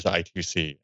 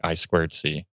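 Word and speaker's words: I²C: Like forever, I thought it was I2C, and then it turns out it's actually I squared C.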